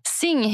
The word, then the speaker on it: Singh: Och eh, fick jag till efternamnet Singh? – Helt rätt. Bra uttalat. – Underbart. Och eh, då blir jag nyfiken. Efternamnet Singh, vilket ursprung kommer det ifrån? Mm. – Singh heter oftast eh, de som kommer från norra Indien, från en stadsdel som heter Punjab.